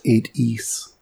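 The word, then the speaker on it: 8 Eyes: It's actually pronounced 8-E's.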